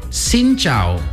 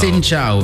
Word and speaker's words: Xin chào: Okay, it's Xin chào. – Xin chào. – Can you say that properly? – Xin chào. – Xin chào. Very good.